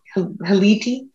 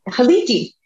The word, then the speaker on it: Haliti: We have host of Haunted 518, Juliana, and I'm going to say it wrong again. Is Haliti? – No, that was right. – Oh, okay. Haliti.